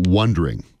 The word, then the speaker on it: wondering: Well, when you pronounce that, it sounded like wanderers, you said again. – Oh, wondering. Wondering.